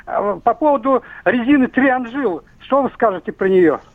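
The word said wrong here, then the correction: Triangle: Триангл, правильное произношение резины, то есть треугольник.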